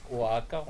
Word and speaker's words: Oaxaca: Au Mexique, la commune de Oaxaca, bon, ça s'écrit Oaxaca, ça se prononce Oaxaca,